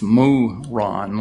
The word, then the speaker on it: myron: It's spelled M-Y-R-O-N, but it's pronounced moo-ron, like a cow moos, or the name of a man, Ron, moo-ron, which is defined here, or says it's probably of foreign origin, myrrh, perfumed oil, or ointment.